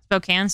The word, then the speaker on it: Spokane: Spokane, sorry.